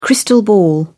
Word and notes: crystal ball: British English

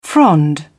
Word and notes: frond: British English

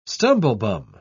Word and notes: stumblebum: British English

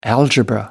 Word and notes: algebra: American English

